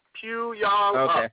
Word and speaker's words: Puyallup: How do you say the first part of it? – Oh, Puyallup. Puyallup. – Okay. – It's named after an Indian tribe here in Washington State, Puyallup.